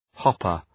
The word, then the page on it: hopper: ‘hɒpər